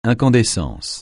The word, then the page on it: incandescence: francais